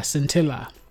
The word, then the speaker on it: Scintilla: But I'm talking about Scintilla. That's the name of the artist. – Oh, that's how you pronounce it.